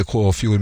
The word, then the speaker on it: Kåfjord: Positivt, i hvert fall for Simon på ungdomsskolen da i Kåfjord, eller Kåfjord Meta. Var det riktig uttalt, ja? – Ja, det var jo faktisk det.